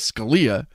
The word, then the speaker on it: Scalia: It's Scalia, or it's Scalia, and then I started traveling, and nobody, you know, n- nobody I didn't correct anybody. They pronounced it the Italian way, which is Scalia.